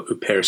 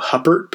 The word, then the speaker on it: Huppert: I had called her until very recently Isabelle Huppert because that's how you spell her last name H U P P E R T. Um, but of course, of course, you don't pronounce that Huppert And then you have this issue where how French do I make it? I don't really speak French, so I think probably you know the the correct pronunciation might be something like Huppert.